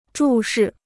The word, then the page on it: 注视: zhù shì